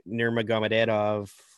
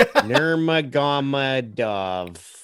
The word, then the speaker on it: Nurmagomedov: Uh, Khabib Nurmagomedov I did not say that right, but what'd you, what'd you call Go me? home, you're drunk. yeah. – Nurmagomedov. – Nurmagomedov.